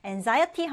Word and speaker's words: anxiety: 자, 그리고 우리 명사형으로 anxiety 하게 될 때는 이때는 z 발음을 해주는데 anxiety 하면 불안, 걱정이라는 뜻입니다.